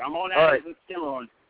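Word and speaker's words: Schermerhorn: So I am Schimmerhorn, I think. How do you pronounce it? Because I don't think I've ever pronounced it the way you did. – Skimmerhorn.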